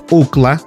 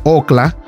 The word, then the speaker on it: Ookla: Ahora bien, en inglés se dice UCLA, pero en español se pronuncia OCLA.